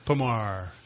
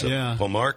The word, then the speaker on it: Pommard: Pommard. – There's a D at the end, but it's Pommard clone from Jane's Vineyard, which we are familiar with.